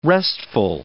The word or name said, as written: restful